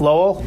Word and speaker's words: Lowell: You're going to play up at a school, UMass Lowell. Is that correct? – Yes. – Lowell? Did I pronounce that right? Yes.